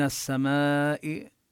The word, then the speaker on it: السماء: من السماء كذلك هنا الألف واللام بلفظ السماء تدغم اللام بالسين، السماء ولا نلفظها، من السماء لا تلفظ اللام.